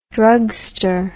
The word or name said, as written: drugster